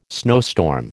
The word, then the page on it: snowstorm: American English